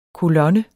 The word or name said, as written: kolonne